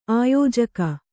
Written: ಆಯೋಜಕಿ

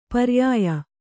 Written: ಪರ್ಯಯ

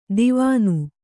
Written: ದಿವಾನು

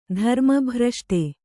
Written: ಧರ್ಮಭ್ರಷ್ಟ

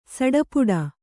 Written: ಸಡಪುಡ

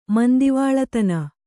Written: ಮಂದೆವಾಳತನ

